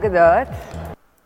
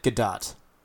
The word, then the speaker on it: Gadot: I'm Gal Gadot. – Gal Gadot. She pronounces it Gadot.